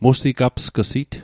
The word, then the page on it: musigapsg'sit: Mi'gmaq